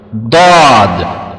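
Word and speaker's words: Дад (ضاد): Дад, чтобы дад говорить, одна сторона язык, или правую сторону, или левую сторону, надо поднять и прижимать к верхними зубам, зубами, большими зубами, напрямой. Дад.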